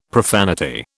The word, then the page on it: profanity: British English